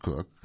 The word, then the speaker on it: Koch: Cook, whose name is pronounced Cook, and it's spelled K-O-C-H, that's why they interject that, she hopes to be among them.